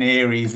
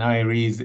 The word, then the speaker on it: Eyeries: You keep saying Aries. It's not Aries. Aries is a star sign, and Aries is the place to be.